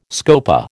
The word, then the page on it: scopa: American English